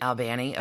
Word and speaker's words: Albany: That's how that's pronounced, right? – Albany, of course.